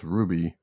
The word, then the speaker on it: RWBY: Next up, DC unites the Justice League and RWBY, which I believe is pronounced Ruby, in a new miniseries this April.